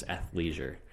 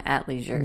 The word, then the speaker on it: athleisure: It's athleisure. – Athleisure. A-T-H. – A-T-H, leisure. – The H is silent. I'm calling it at leisure.